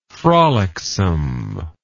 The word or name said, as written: frolicsomeness